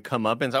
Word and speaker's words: comeuppance: If you were to ask me how many syllables were in comeuppance, I would have guessed 6. – Yep. Yeah, I was going to say, Andy, that's like half the syllables right there. – Comeuppance. – It's only 3.